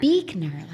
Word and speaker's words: Buechner: Which I always said the name wrong in my head. I just saw it written and I thought it was like Buechner or something. Say it again. – Beekner, like Beek.